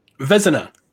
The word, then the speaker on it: Vezina: Goalie, is it the Vezina? How do you say that? – Vezina.